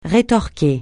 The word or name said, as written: rétorquer